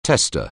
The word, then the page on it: tester: anglais